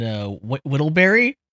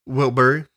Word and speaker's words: Whittlebury: We're glad to have you here from the F1 in Silverstone Circuit near the Northamptonshire village of Silverstone and Whittlebury. Is that right? – It's actually pronounced Wilbury.